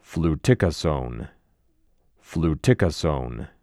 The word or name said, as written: Fluticasone